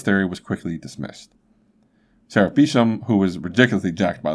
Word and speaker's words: Beauchamp: His last name is spelled B-E-A-U-C-H-A-M-P, so I've always pronounced it as Beauchamp, because that's kind of what it looks like, but apparently it's Beesham.